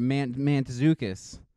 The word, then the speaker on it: Mantzoukas: Mantzoukas? – There's an N in there. Mantzoukas.